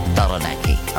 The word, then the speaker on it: Taranaki: Taranaki.